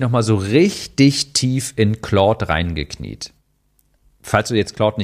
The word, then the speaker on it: Claude: Claude, damit ist die künstliche Intelligenz gemeint, falls ihr noch nicht kennt, Claude geschrieben, halt französisch ausgesprochen, Claude. Claude.ai.